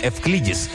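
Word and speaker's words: Эвклидис (Εὐκλείδης): На Луне есть кратер под названием Эвклидис. Так по-гречески произносится имя математика, которое мы называем Эвклидом.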